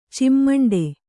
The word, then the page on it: ಚಿಮ್ಮಂಡೆ: cimmaṇḍe